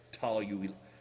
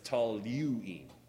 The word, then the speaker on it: toluene: So this much is toluene, one of the most mispronounced words in chemistry. Even myself, if I'm not careful, I'll say toluene. Toluene is what comes out, but it's toluene, just like nuclear and nuclear.